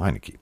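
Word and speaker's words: Heinicke: Washington hat einen Quarterback, der heißt tatsächlich so wie das, also so wird in Amerika Heinecke ausgesprochen, Heinecke.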